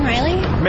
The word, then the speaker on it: Riley: Megan Riley. – Megan Riley. How do you spell that last name? – R-I-L-E-Y. – R-I-L-E-Y, is yes.